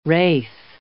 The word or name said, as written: wraith